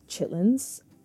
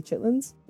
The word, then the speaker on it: chitterlings: So a holiday delicacy that my family eats during Christmas time is something called chitlins. Actually, at the grocery store, the label will say chitterlings, but no one I know actually pronounces it that way. We say chitlins.